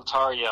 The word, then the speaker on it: Tarja: Is that how it's pronounced, Tarja? – That's how it's pronounced, yes.